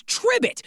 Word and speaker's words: Tribbit: It's Tribbit. Rhymes with ribbit.